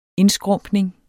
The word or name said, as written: indskrumpning